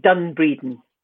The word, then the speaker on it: Dun Breedin: So the project is called Done Breeding. Have I pronounced that right? – Yeah, D-U-N-B-R-E-E-D-I-N, Done Breeding.